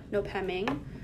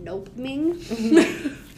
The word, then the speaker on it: Nopeming: The name Nopeming, which for a long time I was calling it Nopeming. – How do we spell it now that we're here? – It's basically like Nope, N-O-P-E, M-I-N-G. – M-I-N-G, okay.